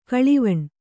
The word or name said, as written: ಗಾಳುವೆಣ್